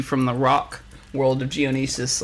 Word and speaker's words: Geonosis: technological, and there's lights everywhere, and then you're back out into Geonosis again, and it's like, oh, okay, I still have it's Geonosis. – Geonosis. – Just so we're all on the same.